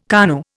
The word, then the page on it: KANU: Indian